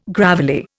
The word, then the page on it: gravelly: Indian